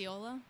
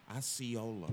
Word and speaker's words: Osceola: I don't know how to pronounce it. – Osceola. – Osceola, okay.